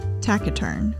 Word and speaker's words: taciturn: It is spelled T-A-C-I-T-U-R-N, and I thought it was taciturn until I looked it up and they told me it was taciturn.